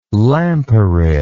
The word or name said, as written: lampyrid